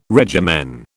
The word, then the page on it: regimen: British English